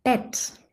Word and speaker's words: Bett: Dann haben wir zum Beispiel das Wort Bett. Hier schreiben wir auch ein E und wir sprechen ein Ä aus.